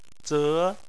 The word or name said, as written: ze2